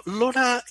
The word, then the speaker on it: LoRa: And now, so the big thing behind Semtech, right, is the LoRa technology, right, which is L-O-R-A. But I was told it's pronounced just like the lady's name, LoRa, right? – Absolutely, yeah.